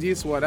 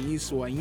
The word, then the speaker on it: Nyeswah: The N is silent, actually. It's like Niswa. Niswa. Or the Y is silent. The Y is silent. Sorry.